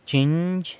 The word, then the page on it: chinzh: San Lucas Quiaviní